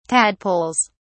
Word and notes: tadpoles: British English

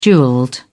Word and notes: jewelled: British English

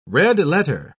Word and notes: red-letter: British English

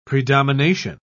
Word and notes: predomination: British English

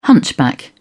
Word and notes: hunchback: British English

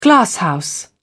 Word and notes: glasshouse: British English

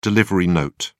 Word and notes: delivery note: British English

